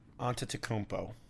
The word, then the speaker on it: Antetokounmpo: can you pronounce his last name for me so I can get it right? – Antetokounmpo.